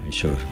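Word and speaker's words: Verisure: Securitas Direct es ahora Very Sure. Eso aprendido, yo creo que ya lo sabéis, Very pero Sure. Very Sure, ay, que me lo dice, eso es. Bueno. Very Sure. Lo digo bien.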